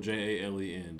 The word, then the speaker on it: Jalen: My name is spelled J A L E N, the most simple way to spell Jalen.